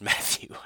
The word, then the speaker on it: Mathieu: I believe his last name is pronounced Matthew. I don't know. I mean, it is, but it's spelled weird, so you never know.